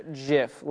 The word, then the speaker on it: GIF: But he says it's pronounced GIF, like the brand of peanut butter, rather than with a hard G sound.